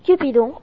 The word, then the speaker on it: Cupido: Je vais vous présenter Cupidon. Dans la mythologie latine, dieu de l'amour correspond à l'Eros grec. Dieu de l'amour dans la mythologie romaine, Cupidon en latin se prononce Cupido.